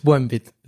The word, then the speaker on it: Buenbit: Yeah, it's Buenbit, like in Buenbit. Bueno, good bit. Yeah. That's a good bit.